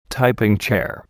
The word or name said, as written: typing chair